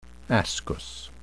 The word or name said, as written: ascus